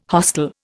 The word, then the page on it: hostel: Indian